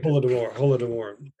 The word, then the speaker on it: Holodomor: Holodomor.